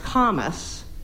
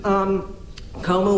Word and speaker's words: Comus: So some years later, I found out that it was not French, it was Latin, and it was supposed to be pronounced Comus, but Comus was the crew in Mardi Gras that used blackface during the Mardi Gras parade, so I was really glad I had mispronounced it.